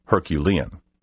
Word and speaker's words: Herculean: The word may also be pronounced Herculean. The unusual word Brobdingnagian is spelled capital B, R-O-B-D-I-N-G, N-A-G-I-A-N.